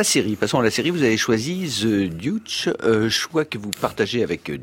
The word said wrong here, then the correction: The Deuce: The Deuce.